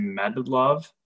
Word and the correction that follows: Medvedev: Medlov? – Vedev or whatever? – Yeah. Danielle. Danielle. – Yeah. Was it good? – Danielle Medvedev. Yeah.